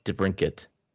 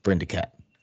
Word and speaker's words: DeBrincat: And I actually said his name right, because normally I call him Brindicat, but you guys always make fun of me when I do that.